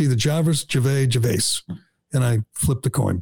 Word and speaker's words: Gervais: And you didn't point that out to me, but I kept saying Ricky Gervais, and then I'd say Gervais. – Well, I didn't want to just, you know, stop and be like, actually, it's Gervais.